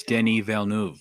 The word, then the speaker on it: Denis Villeneuve: It's Denis Villeneuve.